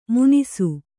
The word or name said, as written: ಮುನಿಸು